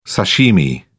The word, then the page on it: sashimi: British English